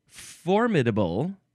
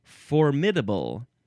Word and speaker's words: formidable: It is the word formidable or formidable. You can emphasize either one of those syllables. And I sort of go back and forth. I think I typically say formidable for some reason. That one just seems right to me.